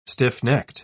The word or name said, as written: stiff-necked